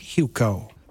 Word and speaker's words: Hewko: At Kate Hucko. That's K-A-T-E. Hucko is spelled H-E-W-K-O.